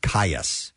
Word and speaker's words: Kaius: K-A-I-U-S. How do you pronounce that? K-A-I-U-S. – Caius.